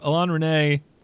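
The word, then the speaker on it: Alain Resnais: That is Last Year at Marienbad, directed by Alain René. Is that how you pronounce it, Nick? – Yeah. – Local pronunciation expert. So Alain René, I love the IMDb summary of this film.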